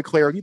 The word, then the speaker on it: Clardy: Hit me up on Twitter at Troy Clardy. The last name is spelled C-L-A-R-D-Y.